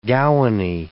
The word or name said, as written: gowany